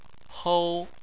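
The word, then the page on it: hou: tone 1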